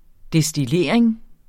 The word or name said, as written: destillering